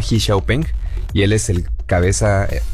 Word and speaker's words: Xiaopeng: De hecho, en China lo pronuncian así, Xiaopeng.